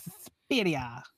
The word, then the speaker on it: Suspiria: I always love saying it that way. Suspiria. – I think that's the proper Italian pronunciation.